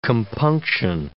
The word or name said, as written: compunction